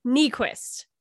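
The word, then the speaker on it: Niequist: Don't mispronounce her name. It's Nyquist. – And how do you say it? – Nyquist. – Nyquist. – Because it's the opposite of what it looks like.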